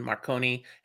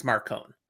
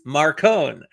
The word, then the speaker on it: Marcone: Now, we did throw it out there the last time we talked about Dresden Files, how to pronounce Marcone, Marconi, and it was confirmed it's Marcone. – So, but it's Johnny Marconi. – No, it's not. Yeah, it is Marcone, for the record.